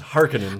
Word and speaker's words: Harkonnen: And I also realized I'm pronouncing Harkonnen the way it is in my brain, not in the movie, which is Harkonnen.